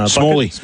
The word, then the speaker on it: Smalley: Smalley. How do you pronounce that one? – Smalley. – Smalley.